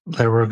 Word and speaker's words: Lehrer: I'm never sure how to pronounce it. It's Lehrer.